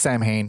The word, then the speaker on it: Samhain: You know, Samhain's the proper pronunciation, but you read it as Samhain.